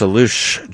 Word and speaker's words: Allouche: Rabbi Pinchas Alush joins us. A-L-L-O-U-C-H-E.